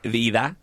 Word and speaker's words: Vida: Yes. We pronounce it Vida. So can you give me a little language lesson one more? – Vida.